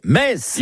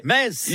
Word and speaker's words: Metz: Le meilleur moyen de mémoriser la prononciation correcte de la ville de Metz est certainement de se rendre au stade Saint-Symphorien, où vous répéterez jusqu'à plus soif. Ici, c'est... – Metz. – Ici, c'est...